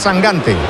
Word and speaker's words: Sangante: No, que Sangante, se pronuncia Sangante. – Yo pronuncio como me digáis. – Bueno, eso lo he oído yo, que dice que es Sangante. – De hecho, me ha dicho, tú acuérdate de Mangante.